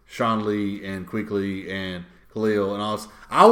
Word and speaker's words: Kuechly: It's just Kuechly. – Kuechly.